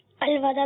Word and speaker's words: الوداع: ماشاء اللہ، آپ لوگ بیٹھ جائیے، درست تلفظ بتائیے، الوداع یا الوداع؟ کچھ لوگ کہتے ہیں الوداع اور الوداع. – الوداع درست تلفظ ہے.